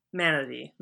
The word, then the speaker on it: Manady: But our guest today is, I actually just realized I didn't check the pronunciation of your last name before I started, Manady. Manady is fine.